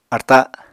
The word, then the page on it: arta: Apatani